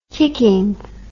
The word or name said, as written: kickin